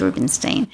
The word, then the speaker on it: Rubenstein: So my name is spelled Rubenstein, so it's Ruben, and we say Stein.